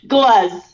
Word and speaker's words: Gluz: How do you pronounce that third? – Gluz. – Gluz, that's it. G-L-U-Z, yeah.